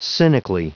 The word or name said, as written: cynically